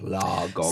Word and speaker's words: Lagom: Yeah, it should be La, like L-A-H, and then Gomme, La Gomme.